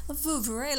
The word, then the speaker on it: Vouvray: Vouvray, like Grey Poupon. – I feel very Vouvray. Vouvray. – Does that smell like Vouvray?